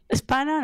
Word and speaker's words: España: And you drop the E. You don't pronounce the E. You just say España.